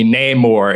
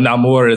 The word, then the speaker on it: Namor: So my enemies call me Namor, and he pronounced it very, very anglicized then. But Namor is all out, like, you know, Talokan says his name.